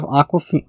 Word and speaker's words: Awkwafina: It's not spelled like the water brand Awkwafina, but it sounds exactly like the bottled water brand Awkwafina. But no, she called herself Awkwafina.